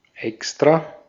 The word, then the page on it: apart: /ə.ˈpɑɹt/; American English